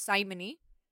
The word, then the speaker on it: simony: It is pronounced simony, mm-hmm. because it is named after Simon Magus, who wanted to buy the gifts of the Holy Spirit in Acts.